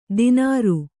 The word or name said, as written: ದಿನಾರು